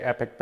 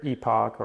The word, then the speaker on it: Epoch: So we'll start with the pronunciation. This varies depending on region for the most part. I tend to say Epic Batteries over in Europe. It's either Epoch or Epoch, depending on exactly where you're at.